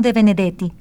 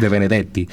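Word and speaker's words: Debenedetti: Y en 1967, Giacomo de Benedetti, escritor italiano. – Oye, lo pronunciaste muy rico, como es, de Benedetti.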